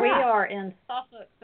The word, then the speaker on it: Suffolk: It's spelled Suffolk, but they pronounce it Suffolk.